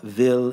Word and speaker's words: Wil: So keep the Will family, Will is how you would pronounce it in German, but W-I-L, keep the Peter Will family, his wife Gabi and their children in your prayers at this time of their loss.